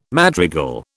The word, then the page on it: madrigal: British English